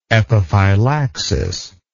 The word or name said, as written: epiphylaxis